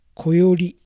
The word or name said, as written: Koyori